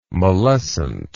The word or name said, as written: mollescent